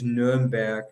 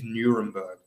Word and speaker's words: Nürnberg: The German pronunciation is Nürnberg, but the British is Nuremberg.